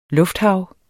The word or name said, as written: lufthav